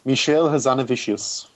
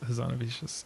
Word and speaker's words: Michel Hazanavicius: Michel Hazanavicius. – Yes, Hazanavicius.